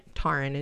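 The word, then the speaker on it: Tairn: Now, Taryn is how Taryn is really how you should pronounce it.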